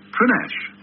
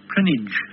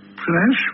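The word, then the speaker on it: Prinknash: And that it's pronounced Prinash. Prinash, with a silent K. – I have also heard it pronounced Prinage.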